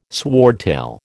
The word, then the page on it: swordtail: American English